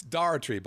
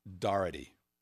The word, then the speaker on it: Daughtry: It's Daughtry, by the way. I've pronounced it 15 different ways. And he's like, all right, you an idiot. He actually wrote it out phonetically. It's Daugherty.